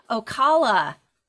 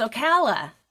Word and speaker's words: Ocala: I've been saying Ocala. It's Ocala. I mispronounced it.